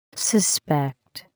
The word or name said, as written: suspect